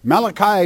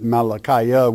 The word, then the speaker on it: Malachi: And many people pronounce it Malachiah, which is to say messenger of God. But inasmuch as it's God speaking, we'll leave it my messenger.